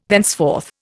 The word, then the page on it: thenceforth: Indian